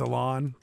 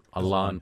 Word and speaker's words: Alan: I was over at Sporting today for their press conference for Alan Pulido, their new striker. Was it? – It's Alon. – Alon Pulido. – I believe it's pronounced Alon.